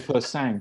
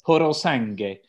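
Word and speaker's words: Purosangue: I thought it was called Persang, but I looked at the phonetic pronunciation. It's Porosangue.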